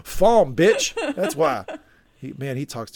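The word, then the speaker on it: Falme: And he says, Falm, because it's pronounced Falm Moraine.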